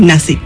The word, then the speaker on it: Nashik: Nació en Nashik, ¿lo digo bien? – Sí, Nashik.